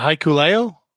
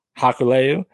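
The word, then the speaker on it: Hikuleo: Can you help me with the pronunciation of Haku's son's name? – Haikuleo. – Haikuleo. That's right. They defeated Finjuice.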